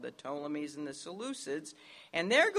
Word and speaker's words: Ptolemies: the Ptolemies, which starts with a P, but the P is silent, the Ptolemies of Egypt and the Seleucids of Syria.